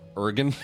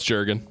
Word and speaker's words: Jurgen: Are you pronouncing it Jurgen or Jurgen? – The J is silent. – Ergen. – Ergen. Ergen. Okay. – It's ergonomics. – Ergen. Okay. – Ergen. – No, it's Jurgen.